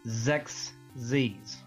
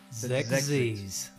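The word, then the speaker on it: Xexyz: So, according to the pronunciation on Wikipedia, it is pronounced Zexies. – Zexies.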